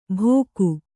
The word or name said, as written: ಭೋಕು